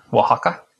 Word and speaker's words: Oaxaca: And he goes to, I don't know how to pronounce this place, O-A-X-A-C-A. How do you say that? – Oaxaca. – Oaxaca.